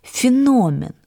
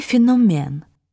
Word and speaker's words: феномен: «Так вот, по-современному произносить это слово нужно с ударением на «о» — «феномен», а вы произнесли «феномен».